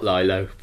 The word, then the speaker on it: Lilo: It's spelt Lilo, but it's Lilo.